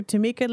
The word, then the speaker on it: Tamika: If you have questions and you want them answered here on Dash Radio, Rocking with Dr. Tamika, let me encourage you and just tell you, go to my Instagram, hit me up at I am Dr. Tamika. Again, that's, I told you, Tamika is spelled all kinds of ways,